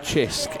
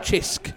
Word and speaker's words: Cisk: No, they have a local beer called Chisk, and it's really nice. It's similar to Madry, actually. – Called what? – Chisk. – Oh, okay. – It's spelled C-I-S-K.